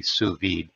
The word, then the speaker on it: sous vide: And sous vide is spelled S-O-U-S space V-I-D-E, sous vide.